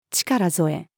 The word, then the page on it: 力添え: female